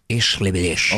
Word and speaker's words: Ich liebe dich: Ich liebe dich.